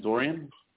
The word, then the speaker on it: Dorian: My name is Dorian. – How do you spell that? – D-O-R. – Yeah. – I-A-N.